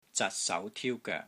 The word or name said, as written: ZAT6 SAU2 TIU1 GOEK3